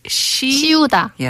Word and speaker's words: 씌우다: Okay, so the 스 and the 시, 씌우다. Yes, a little bit of a pronunciation difference there.